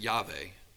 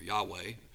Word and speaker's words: Yahweh: We pronounce it Yahweh or Yahweh.